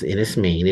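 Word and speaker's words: Enys Men: A few weeks ago on this show, we talked about Innis Main, spelled Innis Men, but pronounced Innis Main.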